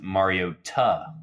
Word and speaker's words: Mariota: I'm sorry, James is pronounced Mariota.